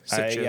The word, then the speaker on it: Sitges: Sitges.